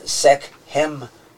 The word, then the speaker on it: Sekhmet: But he was also called Zephaniah in another language, but there's also reference to the fact that he was given this name, Sekhmet. There's a couple different ways that it kind of represented in the hieroglyphics. Sekhmet. It can be pronounced that way.